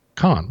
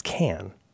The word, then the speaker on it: Cannes: Khan. Well, wait a minute. What Khan is the guy from Star Wars, but I think it's pronounced Can. People say Khan because it sounds fancy.